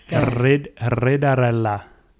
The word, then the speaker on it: ridarella: This next one, I think this is pronounced Was I think this one is a test of how well you can roll your R's. – That's what I was just thinking. – Ridarella.